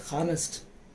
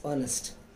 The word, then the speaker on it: honest: We don't say honest, it is honest.